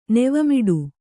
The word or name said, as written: ನೆವಮಿಡು